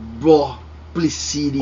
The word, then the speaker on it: Boplicity: Não, como é que se pronuncia isso aí? – Bull. Ah, eu, eu acho que é Boplecity. Ok. Que é um O só. Não é Bull, é Bló. É, é Bó. – Boplicity. Boplicity.